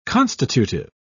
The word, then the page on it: constitutive: British English